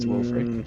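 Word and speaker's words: Wilfyr: It's spelled like Wilfear, but it's pronounced Wilfrey.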